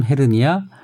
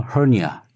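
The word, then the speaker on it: hernia: 한국식 발음 헤르니아, 원어 헐니아.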